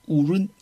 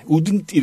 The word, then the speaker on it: wouldn't: 미국 사람들이 거의 우른 이렇게 발음해. 우든 이렇게 발음 안 하고 우른 이렇게 발음해. – 우른? – 우른? 그 발음이 뭐 그 발음 같아. 르 발음이 사실 납니다.